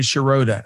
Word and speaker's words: Shiroda: It's like my Shiroda. Tony Shiroda. That's how you remember it.